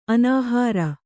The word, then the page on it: ಅನುಹಾರ: anuhāra